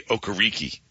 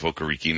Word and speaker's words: Okereke: His first half of his career, he was Bobby Okereke. And then he decided, you know, it was not pronounced that way. He called himself Okereke, and then it was going to be Okereke.